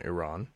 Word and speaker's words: Iran: or sorry, Iran. I prefer the phrase, the pronunciation Iran.